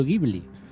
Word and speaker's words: Ghibli: o cómo se pronuncia correctamente, a ver. – Estudio Ghibli. – Ahí estamos.